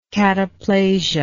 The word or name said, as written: cataplasia